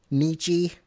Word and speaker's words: Nietzsche: Nietzsche. – Nietzsche yeah.